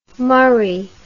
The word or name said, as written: murree